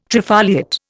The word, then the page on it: trifoliate: Indian